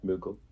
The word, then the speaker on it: Mughal: Yeah, Mughal.